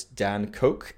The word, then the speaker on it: Koch: Like you said, it's Dan Koch. He spells it as it's pronounced, not as it's spelt. And so D-A-N-C-O-K-E. His name, of course, is K-O-C-H, but it's pronounced like Coke, like the beverage.